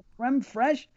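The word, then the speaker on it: crème fraîche: Creme fraiche? It is something? – Yeah, it's spelled like F-R-A-I-C-H, creme fraiche.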